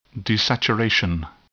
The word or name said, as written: desaturation